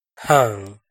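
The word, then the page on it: Haam: Hmong; last name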